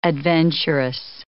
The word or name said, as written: adventuress